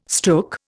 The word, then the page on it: stook: Indian